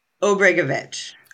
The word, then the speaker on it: Obrigavitch: Obrigavitch.